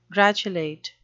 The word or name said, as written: gratulate